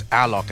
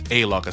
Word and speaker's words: Alok: And for clarification, the name of the artist, along with Jennifer Lopez, is pronounced as Alok, actually, not as Alok, as some would be, as some would presume.